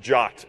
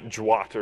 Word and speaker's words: JWT: say that it's pronounced JOT and not JWAT or something like that.